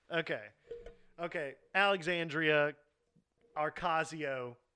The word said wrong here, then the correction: Ocasio: Cortez. All right. There you go. – Ocasio. There's no R.